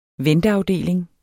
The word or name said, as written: venteafdeling